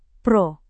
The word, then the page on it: pro: Indonesian